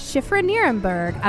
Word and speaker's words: Shifra Nerenberg: Joining me now is Shifra Nerenberg of Spiral Intuitive.